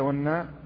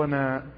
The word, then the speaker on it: ونا: والنون، أو نقول والنون والناء هكذا، والناء أو وناء، لأن هذا يخطئ به فيه البعض، يقول والناء، هذا خطأ.